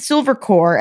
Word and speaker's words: SilverCorp: The company is called Silver Corp USA. I say corp. It's spelled S-I-L-V-E-R-C-O-R-P. Which would be Silver Corp. He pronounces it Silver Corps, as in Marine Corps, but that's usually with an S.